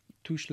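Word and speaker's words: touché: Touche, bitch. – It's pronounced touche.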